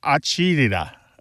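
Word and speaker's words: acidità: And it's pronounced something like acidita, acidita. And oh.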